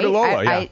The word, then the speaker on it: Rhodiola: And one, it's, I don't know how to say it, Rhodiola? – That sounds perfect. – Right? – Rhodiola, yeah.